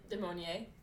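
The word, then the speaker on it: du Maurier: DeMarier.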